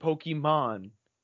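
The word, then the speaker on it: Pokémon: Stop, I'm telling you, every freaking older person literally does not pronounce it Pokemon. – Nah, it's Pokemans.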